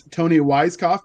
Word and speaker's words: Toni Weisskopf: Next, we have Miss Toni Weisskopf. Did I pronounce that right? I should have asked in the beginning. I never remember. – Yeah, no, that's right. Yeah, that's it.